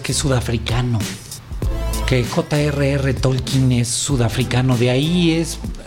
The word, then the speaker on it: Tolkien: Y que así se pronuncia, gracias a la película, nosotros siempre hemos dicho Tolkien, digamos que fonéticamente como se escribe, pero en la película él es muy enfático, el personaje que interpreta, eh, el personaje de la película que es Tolkien, eh, cómo se debe de pronunciar